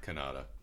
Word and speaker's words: Kanata: Kanata.